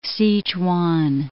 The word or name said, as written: Sichuan